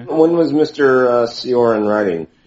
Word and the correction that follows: Cioran: It's Chorin, I believe.